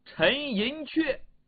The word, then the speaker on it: 陈寅恪: 这个恪字啊，也是个多音字，用在人名上的时候，往往读却。比方说，我们知道的大历史学家陈寅恪，就这个字。